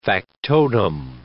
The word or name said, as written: factotum